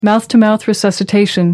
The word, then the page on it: mouth to mouth: American English